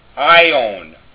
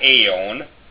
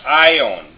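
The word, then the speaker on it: ion (αἰών): The first one, alpha iota, it's pronounced I. It's not A. It's I, as in the Greek form ion. It's not aeon. It's ion, meaning age in the scriptures.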